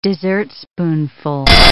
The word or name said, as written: dessertspoonful